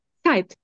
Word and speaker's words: Zeit: Zeit wird immer Zeit. TS. Zeit.